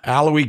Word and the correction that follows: Alohi: Gilman, and if I'm mispronouncing his first name, which I probably am, I apologize. – Alohi. – Alohi, thank you.